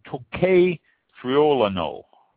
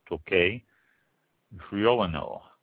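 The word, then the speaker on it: Tocai Friulano: So, next one, Tocque Friulano. That's spelled T-O-C-A-I, Tocque Friulano, F-R-I-U-L-A-N-O.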